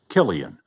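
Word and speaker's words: Cillian: "2023 was the year the world learned to pronounce Killian.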